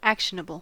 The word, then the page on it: actionable: American English